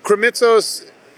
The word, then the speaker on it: Krimitsos: But Krimitsos is easy to say, but it's hard to pronounce when you're looking at the words.